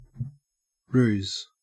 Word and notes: ruse: Australian English

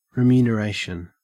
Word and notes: remuneration: Australian English

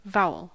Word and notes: vowel: American English

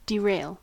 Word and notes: derail: American English

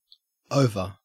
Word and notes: over: Australian English

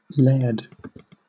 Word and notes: laird: British English

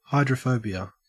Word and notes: hydrophobia: Australian English

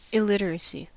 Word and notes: illiteracy: American English

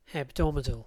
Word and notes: hebdomadal: British English

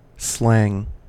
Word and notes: slang: American English

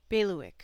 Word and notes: bailiwick: American English

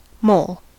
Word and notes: mole: American English

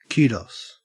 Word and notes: kudos: Australian English

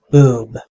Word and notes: boob: American English

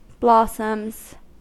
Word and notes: blossoms: American English

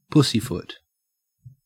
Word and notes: pussyfoot: Australian English

